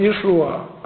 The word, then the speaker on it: Иешуа: Оно произносилось Иешуа, а не Иисус или Иисус.